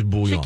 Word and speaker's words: bouillon: Do you pronounce the L's? – Bouillon. I don't think so. – Oh, okay. No. Okay. It's bouillon.